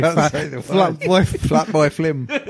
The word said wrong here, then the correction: Fatboy Slim: Fatboy Slim. There we go.